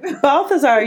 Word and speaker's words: Balthazar: Balthazar, yeah.